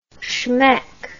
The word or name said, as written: schmeck